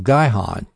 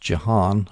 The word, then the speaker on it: Gihon: The upper pool was the pool fed by the spring of Gihon, G-I-H-O-N. Sometimes it's pronounced Jihon, apparently, but I'm going to call it Gihon or Jihon, Gihon.